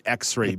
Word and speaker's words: Xrai: So I'm going to spell out X-ray because it's spelt a little bit differently than they might expect. It's X-R-A-I, X-R-A-I. So get that sort of artificial intelligence built into the branding. X-ray glasses, X-R-A-I is how you spell that one.